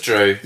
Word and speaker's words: Dryw: It's just Dryw.